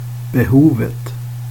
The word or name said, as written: behovet